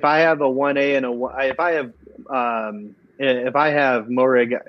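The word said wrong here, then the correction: Moehrig: and how do you say Morig? – Merig.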